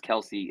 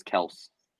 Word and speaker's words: Kelce: I guess he just now decided to say it's not pronounced Kelce, it's Kelce.